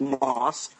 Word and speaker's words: MOS: No, it's just M-O-S, but pronounced Moss.